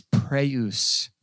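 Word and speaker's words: praeus (πραΰς): The word is praeus, is how it's pronounced in the Greek, which means mild or calm.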